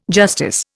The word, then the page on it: justice: Indian